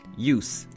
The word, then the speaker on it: use: But something like use and use,One syllable, so change that S to a Z sound.